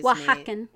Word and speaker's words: Oaxacan: Oaxacan. Wa, like, wa. Like, as yeah. if it were spelled with the W, but it's not. Okay. – It's like an O-A-X, yeah.